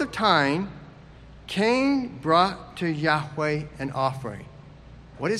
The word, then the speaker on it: Yahweh: By the way, anytime you see the word L-O-R-D in caps, you heard Brandon pronounce it that way. You'll hear me pronounce it. You'll hear most of the men pronounce it that way. It simply in the Hebrew means, or would it be pronounced Yahweh.